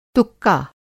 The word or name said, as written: तुक्का